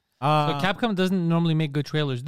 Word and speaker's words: Capcom: Like Capcom is not really good at making trailers, I've noticed. – Did you say Capcom? – Yeah, Capcom. – Capcom, not Capcom. – Capcom. – You pronounce it like C-A-P-C-U-M.